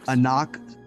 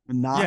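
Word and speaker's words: Anck-su-namun: How do you say it? – Anak. Play that one for me one more time. One more time. – Anak. Yeah, like that. That's how you say it.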